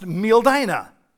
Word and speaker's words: Mildina: It's not spelled like it sounds, but it is pronounced Mildina.